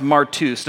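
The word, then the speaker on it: martus (μάρτυς): That word is pronounced martus. Does it look like another word to you? Martyr. It's the same.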